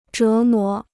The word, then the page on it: 折磨: zhé mó